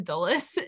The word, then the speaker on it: Dulles: If you guys listening don't know, but we were in West Virginia earlier this year and we flew into Dulles, if that's even how you say it.